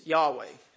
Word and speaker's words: Yahweh: Now the name of God, we believe it's pronounced, is Yahweh. We don't actually have the vowels from the Hebrew.